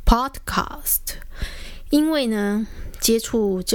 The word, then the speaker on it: podcast: 那所以我也没有去注意说，诶，这样子其实这种美国来的东西，这个是一个外来语哦，所以应该要尊重原创者的发音哦，应该叫做 iPod，叫做 podcast。